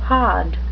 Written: pod

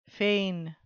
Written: fain

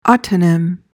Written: autonym

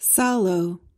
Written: sallow

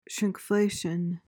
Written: shrinkflation

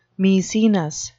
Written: maecenas

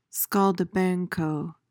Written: scaldabanco

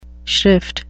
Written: shrift

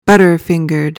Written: butterfingered

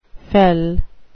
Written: fell

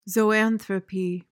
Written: zoanthropy

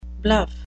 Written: bluff

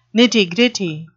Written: nitty-gritty

